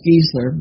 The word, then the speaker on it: Geisler: It's pronounced Geisler, by the way.